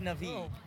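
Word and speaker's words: Na'vi: And it's pronounced Navi, bro.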